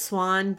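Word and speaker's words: Siuan: we meet Suwan. Is that how to say it? – I'm pretty sure it's Swan, but Swan. I always say Suwan.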